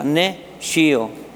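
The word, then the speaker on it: nescio: Son dos palabras que en latín una se pronuncia ne y la otra se pronuncia shio, S-C-I-O. ¿Y qué significa ne shio?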